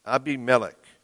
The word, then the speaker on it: Abimelech: Abimelech. Abimelech in Hebrew is what it would be pronounced.